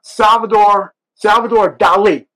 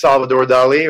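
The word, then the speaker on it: Salvador Dalí: You brought Salvador Dali, right? Am I saying correctly? – Yeah. – I pronounce it correctly for everybody listening at home. – Well, it depends if you're in Spain or in Europe or in the States, but typically it's Salvador Dali, or you could say Salvatore Dali.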